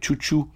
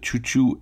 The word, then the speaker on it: Xiu Xiu: On commençait avec Chou Chou, groupe qui s'écrit X-I-U-X-I-U.